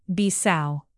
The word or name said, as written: bissau